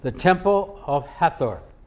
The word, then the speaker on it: Hathor: I start Hathor, H-A-T-H-O-R.